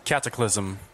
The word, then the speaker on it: Cataclysm: för att eh, eh expansionen heter Katak Ja, du som kan din engelska, hur uttalar man det? Cataclysm. Tack så mycket, tack så mycket.